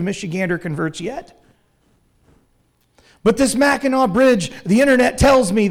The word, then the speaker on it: Mackinac: It's pronounced the Mackinac Bridge, right? Even though there's a C on the end.